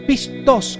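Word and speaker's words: pistos (πιστός): Estamos aquí por un pistos, pistos con acento en la O, porque queremos ser fieles, queremos ser dignos de la cruz de Cristo.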